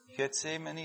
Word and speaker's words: Gethsemane: En hij zei, spreek je dat goed uit, Getsemane? Ja? Gethsemane, je hebt daar zoveel verschillende. Iemand dat Hebreeuws kent? Gethsemane, we zullen het zo uitspreken. Hij zei, blijven jullie hier zitten, ik ga daar bidden.